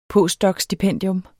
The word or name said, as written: postdocstipendium